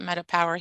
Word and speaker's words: Meta PWR: So the again, it's called Meta, M-E-T-A, P-W-R. But like I said, we call it Meta Power here in our house.